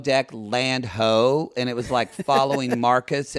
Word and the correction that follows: Marcos: in a restaurant... – Marcos.